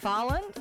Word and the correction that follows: Haaland: Is that how you say his name? – Haaland.